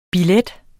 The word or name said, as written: billet